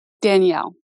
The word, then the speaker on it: Danelle: Um, well, so I first want to ask do you pronounce your name Danelle or do you pronounce it Danielle? – Danielle.